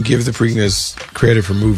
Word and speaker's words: Preakness: The Preakness? Preakness? – The Preakness. Yeah, Preakness. Preakness Stakes.